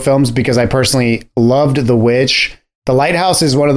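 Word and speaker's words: The VVitch: This is a director that I personally really love, who debuted with The Witch. – I think it's pronounced the Vavitch. – No, it's not.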